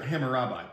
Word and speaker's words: Hammurabi: I mean, and cool things like um, Hammy Robbie. No, I got that name wrong. – Right, I know who you mean though. Go ahead. With the boats. – Mm-hmm. – Was that right? – Ham- I, I always heard it Hammurabi, but go ahead. I could be mispronouncing that too, though.